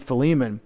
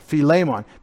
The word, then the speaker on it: Philemon: We don't know, but it's evident in part as you read Philemon. Philemon. Why did you say Philemon? Philemon. Because in the Greek, it's phile. The accent is on the A.